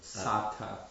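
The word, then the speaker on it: sāta: Excuse me, the A is long, so Sata.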